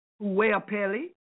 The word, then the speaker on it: Goapele: Her name is spelled G-O-A-P-E-L-E. And from my understanding, it's pronounced Huapele. I'm getting better at that.